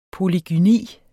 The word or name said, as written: polygyni